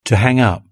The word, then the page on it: to hang up: British English